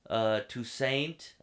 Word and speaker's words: Toussaint: Idris Elba, Jamal Jerome, Lorraine Toussaint.